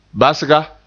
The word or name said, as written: basga